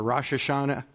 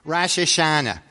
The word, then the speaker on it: Rosh Hashanah: where I sound the shofar for Rosh Hashanah. Oh, I didn't say it right. I'm from Philly. Rosh Hashanah.